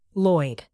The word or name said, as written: lloyd